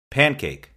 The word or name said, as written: pancake